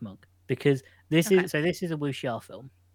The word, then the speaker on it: wuxia: I was wondering how you pronounce that, because in my head, wuxia, but I'm glad you said that out loud.